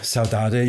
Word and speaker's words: saudade: Altså, de har jo kaldt den for, hvis jeg udtaler det korrekt, saudade, som er portugisisk. – Ja, saudade. Jeg tjekker lidt omkring udtalelsen, og der er nogen, der siger saudade, og så er der nogen, der siger saudade.